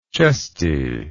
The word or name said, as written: chestiness